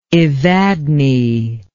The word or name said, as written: evadne